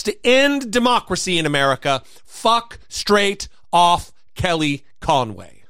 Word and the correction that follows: Kellyanne Conway: Kellyanne Conway. – Kelly Ann Conway. – Yes. – Another syllable.